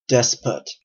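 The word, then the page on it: despot: Canadian English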